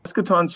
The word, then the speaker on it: Eschaton: Eschaton Solutions, and it's a lot of people get the pronunciation wrong. It's eschaton.